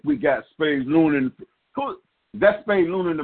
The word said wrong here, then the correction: Spade Lund: Spade Lund, yes indeed. Spade Lund. I said it wrong.